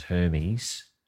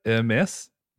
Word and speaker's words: Hermès: Heir to the French fashion house Hermès, which I forthwith shall pronounce as Hermes, because that's what we would do. And you sound less racist just mispronouncing stuff these days than you do doing it properly, and less of a wanker. – So how do you say it? Hermès? – Yes.